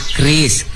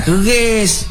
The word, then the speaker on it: QRIS: Kris, Bu. Kris, kris. Q R I S. Dibacanya kris bukan keris.